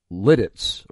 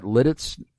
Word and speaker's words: Lititz: I mispronounced the city. I now understand, according to this listener, it's Lititz. Am I right? Is that Lititz? – You are correct. You nailed it. Lititz, exactly.